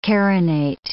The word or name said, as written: carinate